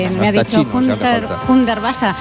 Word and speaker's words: Hundertwasser: la Casa Hundertwasser, ¿verdad? No sé si lo he dicho bien, que sí, el alemán bueno, es lo mío. – Me lo han corregido antes, Hundertwasser. Es Hundertwasser.